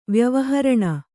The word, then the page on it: ವ್ಯವಹರಣ: vyavaharaṇa